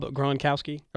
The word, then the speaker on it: Gronkowski: You know, apparently, this Gronkowski fella. Am I pronouncing that right? – I believe that's right. Rob Gronkowski.